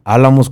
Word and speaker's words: Álamos: El cronista de Álamos Sonora, Álamos con acento en la A.